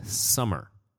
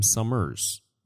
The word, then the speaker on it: Summer: And I said that correctly this time because I want to say during the interview, I kept calling him Summers. And there's no S at the end of his last name.